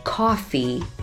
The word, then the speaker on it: coffee: 很好，咖啡这个单词大家要注意它的发音，是 coffee C-O-F-F-E-E coffee，不要按照中文的声音发成了咖啡。好，再来一次相对准确的 coffee。